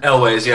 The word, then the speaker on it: Elwes: Is that how you pronounce his last name, Elwes? – Elwes, yeah.